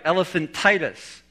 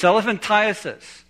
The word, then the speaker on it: elephantiasis: One of you said, pronounce it right, elephantitis. No, that pronunciation comes from hearing it wrong, from hearing the elephantiasis and thinking it's supposed to be titus. So most of us know of this disease called elephantitis. It's elephantiasis, and it comes from a microscopic worm that when the female mates with the male gives the offspring of thousands of microscopic worms.